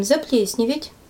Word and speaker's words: заплесневеть: Действие наблюдаем заплесневеть. Это правильное ударение на всякий случай.